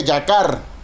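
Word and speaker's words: yacar (יָקָר): La palabra estimada en su original hebreo se pronuncia yacar.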